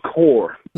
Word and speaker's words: Quor: So how do you pronounce the name of your band again? – We are called Core. Q-U-O-R.